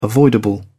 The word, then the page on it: avoidable: British English